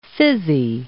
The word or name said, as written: fizzy